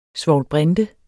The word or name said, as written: svovlbrinte